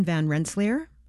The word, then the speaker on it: Van Rensselaer: Schuyler was married to Catherine Van Rensselaer. Am I saying her name correctly? Yes, they were parents to 15 children, 8 who survived into adulthood.